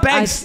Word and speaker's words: Bank: It's just Bank with one K and no S. Stop calling me Banks.